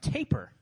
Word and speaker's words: tapir: So my mom's excited that she thinks it's a tapir, which is, by the way, a pig-like South American animal. – I think people pronounce it, taper.